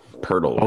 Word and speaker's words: Poeltl: I do like one player prop in this game is Jakob Poeltl, or Poeltl. I don't know how to say his name. – Poeltl. – Okay, there you go.